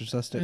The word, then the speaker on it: Sustek: Scott and then S-U-S-T-E-K. – Oh. – Scott Sustic. Sustek. Yeah, Sustek.